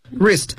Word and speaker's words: wrist: wrist 表示手腕，但是读的时候 W 是不发音的，T 也不能发音。